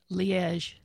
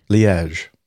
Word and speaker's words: Liège: Yeah, Liège. – Liège.